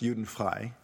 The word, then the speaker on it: Judenfrei: Juden-free, it's actually pronounced Judenfrei, which means free of Jews.